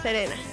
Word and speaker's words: Serena: Y también hacía la voz de Sailor Selena. No sé si lo pronuncié bien. Entonces, Selena, Serena. Serena, lo siento, Serena.